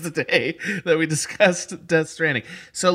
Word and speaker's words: Death Stranding: That's right, Death Stranding, because every character pronounces it with that emphasis, and I want to do honor to this fantastic game pronouncing it correctly.